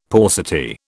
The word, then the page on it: paucity: British English